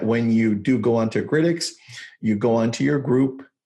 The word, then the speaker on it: Grytics: is there's something called Grytx. I don't know if you've heard of Grytx before. It's spelled Grytics.